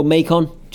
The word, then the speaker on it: Macon: I'd go Macon, just because it sounds more American to me.